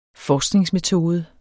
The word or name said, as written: forskningsmetode